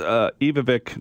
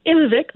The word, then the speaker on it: Ivvavik: Where is Ivvavik National Park? Did I pronounce that right? – Yeah, it's pretty close. Some people say Ivvavik, but any pronunciation is good.